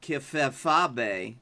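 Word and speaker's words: kayfabe: That is pronounced kifefabe.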